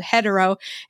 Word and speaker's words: Headero: So I'm so happy to have you on the show to talk about this amazing app that you have put together called Hetero, and it's spelled H-E-A-D-E-R-O.